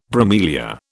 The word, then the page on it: bromelia: British English